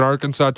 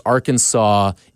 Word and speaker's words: Arkansas: I also saw, too, there was a great tweet out that Illinois versus Arkansas in Des Moines has to hold some sort of NCAA record between teams and location with silent S's. Arkansas, Illinois, Des Moines.